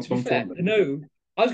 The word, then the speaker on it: Penaud: Damien Penaud. It rhymes. It's lovely. James Lowe, Damien Penaud.